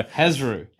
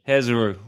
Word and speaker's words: Hezrou: How do you spell it for those playing at home? – H-E-Z-R-O-U. – There you go. – Hezru. – Hezru.